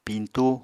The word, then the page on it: piñtú: Apatani